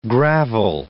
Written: gravel

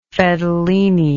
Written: fedelini

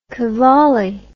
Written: qawwali

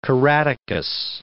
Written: Caratacus

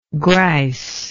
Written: grice

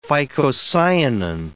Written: phycocyanin

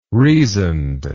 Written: reasoned

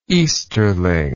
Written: easterling

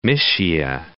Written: Mysia

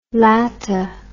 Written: lata

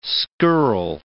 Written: scurrile

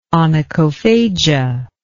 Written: onychophagia

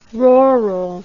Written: roral

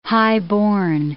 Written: highborn

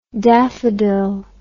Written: daffydill

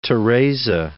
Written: Teresa

